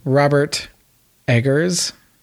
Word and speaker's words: Robert Eggers: But anyway, so Robert Eggers, that's how you say it.